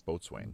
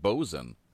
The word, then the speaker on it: boatswain: Boatswain. – Boatswain is how it's pronounced. Yeah. – Well, actually, no. It's pronounced bosun.